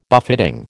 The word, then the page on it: buffeting: American English